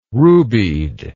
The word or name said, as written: rubied